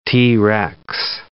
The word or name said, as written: T. rex